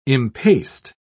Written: impaste

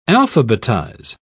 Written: alphabetize

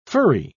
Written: furry

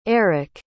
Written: arick

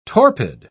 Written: torpid

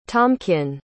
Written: tomkin

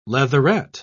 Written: leatherette

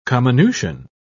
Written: comminution

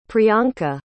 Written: priyanka

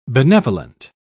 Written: benevolent

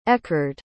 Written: Ekhard

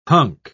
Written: hunk

